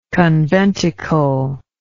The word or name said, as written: conventicular